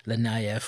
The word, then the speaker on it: Linnea: Is it Linnea or Linnea? Yeah, I think it is Linnea, and I think I've been saying it wrong for years.